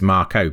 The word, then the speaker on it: Mako: I think we've anglicized it, though, because in Japanese pronunciation, it actually makes sense that it's Marco, because they only have a, e, e, o, u as vowel sounds. So Marco is the Japanese way, I guess, right, of pronouncing it, and we've just anglicized it.